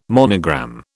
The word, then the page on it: monogram: British English